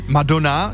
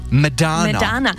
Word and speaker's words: Madonna: To je třeba jako Madonna, je správně Medana.